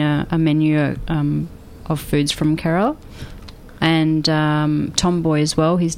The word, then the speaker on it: Kerala: That's yes. That's correct pronunciation. – Kerala.